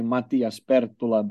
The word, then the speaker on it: Matias Perttula: If you want to be really fancy, you can say Matias Perttula, but I don't expect Wow.